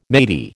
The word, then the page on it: matey: American English